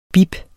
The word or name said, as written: bip